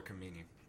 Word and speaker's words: Khomeini: Ayatollah Khomeini and other Iranian hardliners. Yeah, Ayatollah Khomeini.